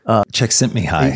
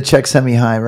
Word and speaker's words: Csikszentmihalyi: Csikszentmihalyi. – Yeah, that. Oh, there you go, the Csikszentmihalyi, right.